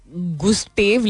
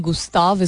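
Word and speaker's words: Gustave: Gustav गुस्टेव लिखते हैं लेकिन गुस्ताव इसको प्रोनाउंस करते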